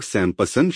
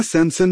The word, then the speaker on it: सैमसन: सैमसन का परिचय अब शिम शोन, आप में से कितने लोग सैमपसन शब्द का उच्चारण करते हैं? इसमें पी के साथ सैमपसन, हम इसका उच्चारण पी से करते हैं। क्या सैमसन में कोई पी है? असल में आप जो कर रहे हैं वह ग्रीक उच्चारण का उपयोग कर रहे हैं। ग्रीक में पी है और यह अंग्रेजी में आ गया है।